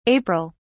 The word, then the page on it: april: British English